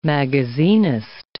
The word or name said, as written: magazinist